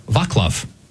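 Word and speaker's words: Václav: Yes, it's actually almost easier in his native language, in Czech, Vaclav. It's fewer syllables. I think it's a little easier.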